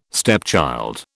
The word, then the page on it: stepchild: British English